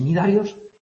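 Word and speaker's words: cnidarios: Estos ctenóforos y nidarios, los dos que empiezan por una C que no se pronuncia, ctenóforos y nidarios, son en los que aparece la neurona.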